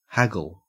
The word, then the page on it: haggle: Australian English